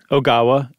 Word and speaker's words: Ogawa: Ogawa, and it's Seiji. Any vowel you see in Japan just screams to be pronounced independently.